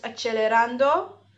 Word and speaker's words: accelerando: It is pronounced accelerando because it is Italian. Please ignore how I say it for the rest of the episode. I was wrong.